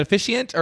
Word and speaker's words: officiant: I am dying to know more about the being a wedding officiant. Well, how do you say it? Is it officiant or official? Like, how do you? – Officiant. Yeah. Okay. Wedding officiant.